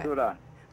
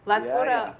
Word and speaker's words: Tura: My favorite song is that Ture Ture. I don't know if I'm pronouncing it correctly. – Tura. – La Tura. Yeah, oh gosh.